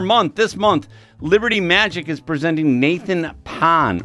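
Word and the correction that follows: Phan: or Fawn, I'm sorry, said his name wrong, Fawn.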